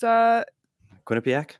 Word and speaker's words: Quinnipiac: ASU played well at Quinnipiac. – Yeah, they scored 2 goals in the first period. First team to score twice against Quinnipiac.